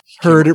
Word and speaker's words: Herd: Herd, it rhymes with nerd.